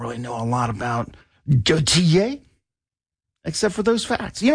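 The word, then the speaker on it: Gotye: That is goat eyes. Nope, you pronounce it Gautier because he's from Belgium.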